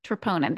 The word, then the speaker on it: troponin: Explain to our audience what troponin, am I saying it correctly, is. – Troponin, there we go.